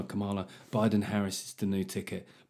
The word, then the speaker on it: Kamala: Well, let me tell you, like, his biggest problem, him and Kamala Harris, because apparently Brits aren't saying it right. We've got Kamala Harris. It says Kamala, for fuck's sake.